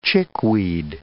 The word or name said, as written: chickweed